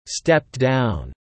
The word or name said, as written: stepped down